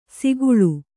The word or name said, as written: ಸೊಗುಳಿ